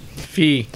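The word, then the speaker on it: Fei: I think it's Faye. – Fee. – It's not Fee. – You pronounced it Fee. – He told me it rhymes with beef. – I don't know. – Because it's F-E-I, so it's because he says it, but it sounds like both at the same time.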